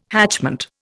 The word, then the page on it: hatchment: Indian